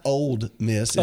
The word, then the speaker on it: Ole Miss: We could talk about all the Ole Miss's national championships in football if you'd like. It's not Ole Miss. It's Ole Miss. – Oh, sorry. – Don't mispronounce it.